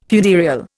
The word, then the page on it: funereal: Indian